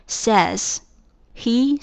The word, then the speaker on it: says: 谓语动词需要加上 s，然后它整体的读音从 say 变成 says，he says，然后前面的整个引号里面的这句话做 says 的宾语。